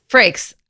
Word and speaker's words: Frakes: Frakes.